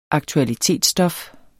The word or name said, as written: aktualitetsstof